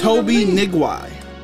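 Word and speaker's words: Toby Nwigwe: It's hard to pronounce. I mean, honestly, I'm sorry. It's Toby Nigwai. – What? Toby who? – It's Toby N W I G W E. Nigwa.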